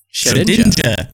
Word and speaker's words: Shedinja: In our notes, we have this fun little Shedinja. Is that how you say his name? – Shedinja.